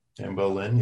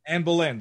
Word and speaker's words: Anne Boleyn: Anne Boleyn, yeah. – Anne Boleyn.